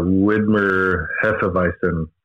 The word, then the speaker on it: Widmer Hefeweizen: The Widmer Hefeweizen, right? Did I say that right? – That is correct.